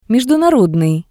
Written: mejdunarodnii

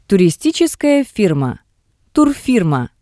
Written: turistitseskaya firma

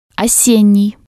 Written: osennii

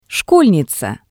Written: shkolniza

